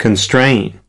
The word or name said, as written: constraining